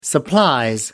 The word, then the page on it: supplies: British English